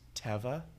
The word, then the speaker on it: Teva: And actually, I learned through working at REI, it's pronounced Teva.